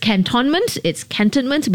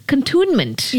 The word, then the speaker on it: Cantonment: So apparently, cantonment... It's cantonment, but it's also, did you know, the British pronunciation that Susan just told me, contunement. – Yeah, correct. – Yeah. – So, you know. – So it's Contunement Road, but I think nobody knows that it's pronounced that way.